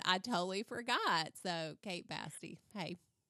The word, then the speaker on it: Basti: Kate Basti. – Basti. – Rhymes with nasty. – It does rhyme with nasty.